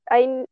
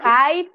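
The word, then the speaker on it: Hye: No sé si lo dije bien, porque no me, eh, sé pronunciar o sea, yo le digo Hay, pero creo que sí se pronuncia Hey.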